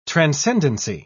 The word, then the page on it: transcendency: British English